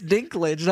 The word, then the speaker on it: Dinklage: It's Dinklage, not Dinklage.